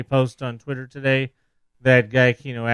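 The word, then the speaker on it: Giacchino: Are we saying his name wrong again? – It was Gianchino, right? – Okay.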